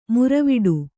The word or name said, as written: ಮುರವಿಡು